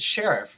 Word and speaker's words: Sherriff: It's Sheriff, right? – Yeah, it's like the cop.